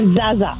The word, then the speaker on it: zaza: Sí, so se pronuncia zaza, es Z-A-Z-A, zaza. – Zada. – Sí, es una palabra que Z-A-D-A, zada, zada.